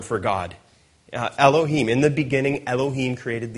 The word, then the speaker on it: Elohim: The one on the left is pronounced Elohim,